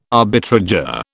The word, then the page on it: arbitrageur: British English